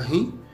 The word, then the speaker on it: REM: Nós temos as fases do sono, né, que são designadas pela sigla REM, né, pronunciada em inglês como o nome da banda de rock REM, que significa Rapid Eye Movement, ou movimento rápido dos olhos.